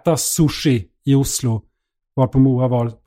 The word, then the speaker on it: sushi: Och då uttalas det sushi. – Det är inget sje-ljud eller tje-ljud. Det är sushi.